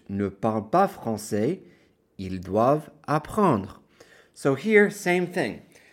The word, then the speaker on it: doivent: The ENT on ILS, DOIVENT, is silent, so you end it on the V.